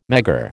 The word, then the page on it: Megger: American English